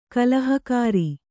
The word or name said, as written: ಕಲಹಕಾರಿ